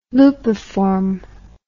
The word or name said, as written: lupiform